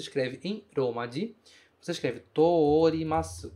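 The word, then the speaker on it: torimasu (通ります): Só que torimasu é uma exceção. Você prolonga o O do TO usando outro O. Você escreve TO desta forma. Ó, TO com outro O. E não TOU. Torimasu. Não é assim o verbo torimasu. Se pronunciaria da mesma forma. Mas não é assim que a gente fala. É sempre to-o nesse caso, certo?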